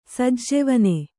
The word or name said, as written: ಸೆಜ್ಜೆವನೆ